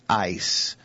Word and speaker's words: eis: And that preposition in Greek is the word eis. Now, if we were going to spell it with English letters, we would spell it E-I-S. – Mm-hmm. – And it's pronounced ice.